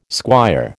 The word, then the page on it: squire: American English